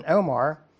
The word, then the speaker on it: omer: And when they did meet it out with an omer, that's how you pronounce it, he that gathered much had nothing over, and he that gathered little had no lack.